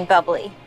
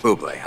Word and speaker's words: Bublé: You mean bubbly? – No, I mean Bublé. – You're Michael Bublé, that's a bubbly. – She's right, Michael Bublé. It's pronounced Bublé, I know, thanks.